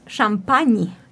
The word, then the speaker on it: champagne: Yes but if you want to pronounce it in French it's champagne